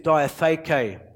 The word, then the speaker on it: diatheke: And the word used in the Greek is diatheke. Diatheke, it's spelled D-I-A-T-H-E-K-E.